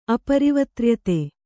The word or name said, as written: ಅಪರಿವತ್ರ್ಯತೆ